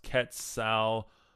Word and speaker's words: Quetzalcoatl: Some people have aligned him with like uh, Quetzalcoatl, uh, and Quetzalcoatl is um, it's uh, Spanish pronounced Quetzalcoatl.